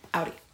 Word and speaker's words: Audi: How is Audi pronounced? As you can tell, just said it. – Audi. Discussion ended.